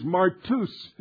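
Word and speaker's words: martus (μάρτυς): Now, you got to understand, that word is martus. It's pronounced martus, and it literally means martyr.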